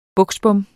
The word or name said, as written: buksbom